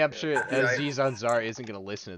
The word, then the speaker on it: Aziz: The whole rest of that cast is so talented that it's like, well, except for Aziz Ansari, because, you know, we do not like him. Isn't that his name, right? Am I butchering his name? – Aziz, yeah.